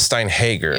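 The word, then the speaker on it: Steinhäger: And I found you say it like A. – Steinhager. – So it is Steinhager.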